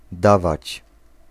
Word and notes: amount: American English; /əˈmaʊnt/

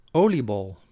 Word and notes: oliebol: /ˈoːliˌbɔl/

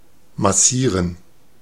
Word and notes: massieren: /maˈsiːʁən/

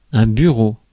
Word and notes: bureau: /by.ʁo/

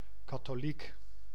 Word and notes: Catholic: American English; [ˈkæθ.lɪk]

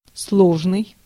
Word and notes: complex: /kəmˈplɛks/; /ˈkɒm.plɛks/

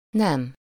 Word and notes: nem: /nɛm/

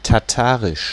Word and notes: Tataars: /tɑˈtɑːrs/

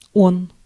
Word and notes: ce: France; /sə/

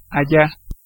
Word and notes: there: [ðɛː]; British English

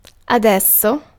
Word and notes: maintenant: [mɛ̃.tø.nɑ̃]; /mɛ̃t(ə)nɑ̃/; /mɛ̃t.nɑ̃/

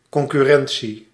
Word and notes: concurrentie: IPA: /kɔn.sʏ.ɾɛn.si:/; Dutch

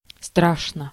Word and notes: badly: /ˈbæd.li/

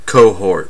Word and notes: cohort: American English; /ˈkoʊ̯.hɔɹt/

